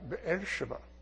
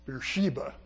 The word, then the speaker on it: Beersheba: There are some cities that have continued on to today, like Beersheba, which most people pronounce Beersheba. It's four syllables.